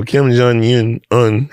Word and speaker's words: Kim Jong-un: He make the un rhyme with Jung. – There you go, you got it. – No. Yeah. So Kim Jong-un.